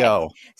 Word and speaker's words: Yeoh: do you say Yeoh? I'm not sure if I'm pronouncing her last name. – I believe correctly. – That's right. Yeah, Michelle okay. Yeoh.